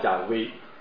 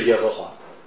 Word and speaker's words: 亚威: 但是今天的人考证出来之后，大概这个读法是叫亚威，而不是耶和华。